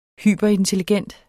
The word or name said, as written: hyperintelligent